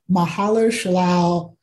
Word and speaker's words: Mahershala: Mahershala Ali become Blade.